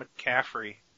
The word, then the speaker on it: McCaffrey: And Ryan McAfee, Am I saying it right? – Ryan McCaffrey. I think it's McCaffrey. – Okay, thank you.